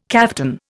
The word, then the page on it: kaftan: Indian